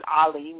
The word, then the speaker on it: Ali: So my name again is Latifa Ali. I like the way you pronounce it, Ali. Maybe that's the perfect way to pronunciate it.